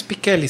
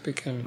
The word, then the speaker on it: Pichelli: Es Pichelli, se pronuncia Pichelli.